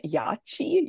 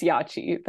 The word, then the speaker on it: Yacchi: And then from the third game, I chose item number 94, I hope I'm pronouncing this correctly, the Helping Yachi, I think it's Yachi, the Helping Yachi. – It'd be chi, yeah. – Y-A-C-C-H-I.